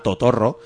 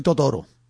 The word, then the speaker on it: Totorro: En francés se pronuncia igual que Totoro, porque como no pronuncian las dobles R's, pero nosotros decimos Totorro, castizamente. – Es como yo después de ir al gimnasio, estoy Totoro.